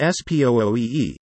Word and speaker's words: SPUI: A single-point urban interchange, or SPUI, pronounced S-P-O-O-E-E, gets its name from the traffic signals that control turns from a single point at the center of the interchange.